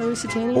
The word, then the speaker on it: Lusitania: Is it Lusitania? Is that how I pronounce it? – Yeah, Lusitania.